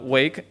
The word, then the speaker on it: Wake: Antes de entrar en el debate Biden-Trump, me gustaría agradecerte que hayas pronunciado Wake, porque yo iba a decir Wake. – Ya, no sabía decir Wake, Waka o Wake, pero... – No, pero Wake está muy bien pronunciado.